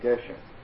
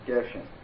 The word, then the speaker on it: Geshem: When I myself examined the matter, there isn't any doubt about it, that the proper pronunciation is Geshem. There's a direct connection, and therefore it should be pronounced Geshem.